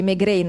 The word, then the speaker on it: Maigret: Ma Malvaldi ha scritto anche i non vecchietti del Barlume, potremmo definirli così, come per Simenon si dice Maigret, non Maigret.